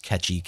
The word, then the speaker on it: Kechi: I think it's pronounced Ketchy, K-E-C-H-I.